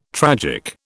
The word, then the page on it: tragic: British English